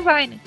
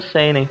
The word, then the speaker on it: seinen: Fonética japonesa, seria seinen. Por isso que eu tô falando seinen, tô até com medo de você saber alguma coisa que eu não sei e eu tô falando errado.